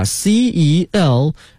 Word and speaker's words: Celsius: 但是我们一般呢习惯用的是这个 Celsius 摄氏温度来表示我们日常的一个体温的感觉，把这个摄氏这个词啊给大家拼一下 CELSIUS CELSIUS Celsius，它的发音是 Celsius。